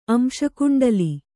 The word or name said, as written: ಅಂಶಕುಂಡಲಿ